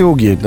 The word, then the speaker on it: угги: «Угги» или «уги» достаточно? – Здесь произносится один звук «г», поэтому, может быть, он на письме потом и останется.